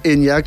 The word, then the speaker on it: Enya: Enya est une approximation phonétique de la prononciation de Eithne en gaélique irlandais.